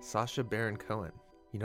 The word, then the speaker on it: Sacha: Sacha.